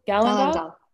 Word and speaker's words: galangal: And it's got carrot, ginger, licorice root, chicory root, beetroot, turmeric, sunflower petals, black pepper, galangal. How do you say that word? Galangal? Galangal mm-hmm.